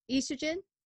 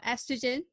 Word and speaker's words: estrogen: And the reason that these symptoms start happening is that two really key hormones in a female's body is estrogen or estrogen, depending on your pronunciation, and progesterone.